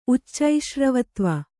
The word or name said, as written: ಉಚ್ಚೈಶ್ರವತ್ವ